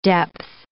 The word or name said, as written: depth